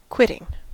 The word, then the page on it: quitting: American English